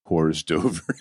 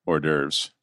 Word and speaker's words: hors d'oeuvres: Homage or Horace Dover. It's like, yes. – This is the best mispronunciation ever. Is that from something, or did you just pop that off the dome? – What's that? Hors d'oeuvres.